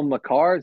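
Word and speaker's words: Makar: Kale McCarr, is that how you say his name? – Yep.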